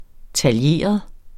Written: taljeret

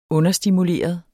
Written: understimuleret

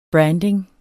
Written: branding

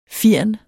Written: firn

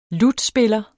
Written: lutspiller

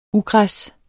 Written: ugræs

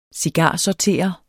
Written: cigarsorterer

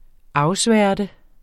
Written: afsværte

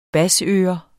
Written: basseører

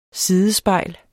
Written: sidespejl

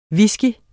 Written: whisky

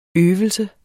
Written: øve

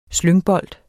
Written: slyngbold

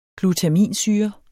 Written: glutaminsyre

